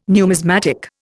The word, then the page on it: numismatic: Indian